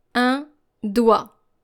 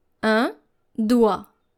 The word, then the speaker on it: doigt: D-O-I-G-T. D-O-I-G-T. This is how you're supposed to pronounce it. Un doigt. Un doigt. So it's very challenging because you are very tempted to pronounce the G and the T, but both of them are silent. Un doigt.